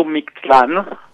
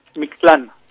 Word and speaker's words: Mictlan: Mictlan, au niveau de la prononciation, Sébastien Rutès, on dit comment ? – On dit plutôt Mictlan, voilà. Mais c'est bien, vous avez bien fait claquer la langue sur le TL, Mictlan.